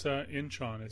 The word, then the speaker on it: Incheon: I think that the actual title is pronounced Icon. – Icon? What? – Yes, I think the N is silent. It's Icon. – No, it's Incheon. It's from the Korean War.